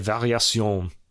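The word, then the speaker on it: Variations: This is a work called Prélude Recitatif et Variés... I'm saying this wrong, God. Prélude Recitatif et Variation.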